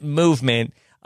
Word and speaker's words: MVMT: MVMT Watches. They pronounce it Movement.